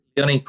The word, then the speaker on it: Liaoning: Liaoning province.